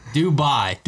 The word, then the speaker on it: Dubai: Dubois. How do I say it? – Dubois. – Dubois. – You put like a random W in there. – That's how I've been saying it. Dubois. – That's not how you say it. That's the point. – Say it again. – Dubai. – Dubai. – Dubai. – Oh, it's bye. – Yeah. – Dubai. – D-O-B-Y.